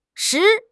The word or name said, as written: 拾